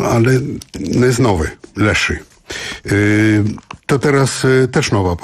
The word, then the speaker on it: Leshy: Spodobał się Państwu zespół z Płocka, który się nazywa Leszy. Pisze się L-E-S-H-Y. Leszy.